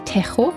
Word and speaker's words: Tejo: Also es ist ein Fluss, der glaube ich auch durch Spanien fließt und in Spanien sagen sie wirklich Tejo, wie ich letzte Woche gesagt habe. – Carajo. – Aber tatsächlich geht es ja hier um Portugal, also hast du es richtig gesagt.